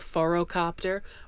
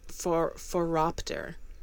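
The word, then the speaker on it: phoropter: Yeah, I keep wanting to pronounce this word as a forocopter, but it's foropter. Foropter, yes.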